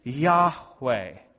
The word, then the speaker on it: Yahweh: It's Yahweh, which may or may not be the actual correct pronunciation. We don't fully know.